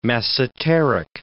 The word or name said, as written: masseteric